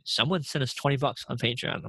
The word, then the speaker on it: Patreon: I would say, because we put up this Patreon. That's how you say it, right? – Patreon.